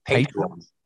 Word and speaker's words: Patreon: And also, you've started a Patreon as well. Is that how you say it? I never say it. I'm too old to say things properly these days. – That's what I've been saying. – Patreon. That's a good word.